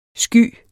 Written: sky

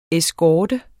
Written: eskorte

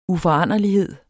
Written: uforanderlighed

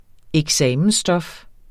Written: eksamensstof

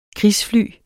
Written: krigsfly

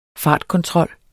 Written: fartkontrol

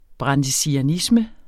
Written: brandesianisme

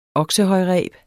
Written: oksehøjreb

